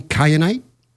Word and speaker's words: kyanite: I noticed that you describe I hope I'm pronouncing this right, green kyanite.